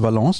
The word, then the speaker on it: Valence: Valence, ist das richtig ausgesprochen? – Stimmt, du wirst wahrscheinlich auch durchfahren.